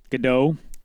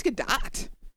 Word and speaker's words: Gadot: Gadot. – I think it's Gadot.